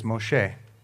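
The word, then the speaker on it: Moshe (משה): The Hebrew pronunciation of Moses is Moshe.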